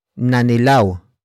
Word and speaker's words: nanilaw: Another example, nanilaw ang ngipin nila. Nanilaw ang ngipin nila. Nanilaw ang ngipin nila. It means their teeth became yellow. Word for word it's nanilaw ang ngipin nila.